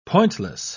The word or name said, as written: pointless